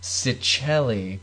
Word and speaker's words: Cicchelli: It's not pronounced how it looks. – Yeah, but it's C-I... We'll link it. – It's C-I-C-C-H-E-L-L-I, which you would think would be... Cicchelli, but it's Cicchelli.